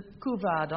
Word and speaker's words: couvade: And now, Anscott James, your word is couvade, C-O-U-V-A-D-E, and in spite of my pronunciation, I perhaps recognize its French in origin, couvade.